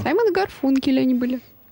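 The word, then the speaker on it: Garfunkel: Вы неправильно произносите имя Гарфункель, написали нам. Гарфункель — это был такой фильм «Семеро смелых». Вот там был Гарфункель, а у нас, Гарфанкель. Я думаю, Гарфанкель, да.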